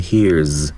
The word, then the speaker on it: hears: And what he hears，那么 hear 这个单词再加 s 的形式的时候要注意它的第三声读成 hears，因为元音结尾的因素呢加了 s 要读成 z。